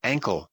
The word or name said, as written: ankle